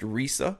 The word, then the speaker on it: Risa: I'm sorry, I believe that's pronounced Risa.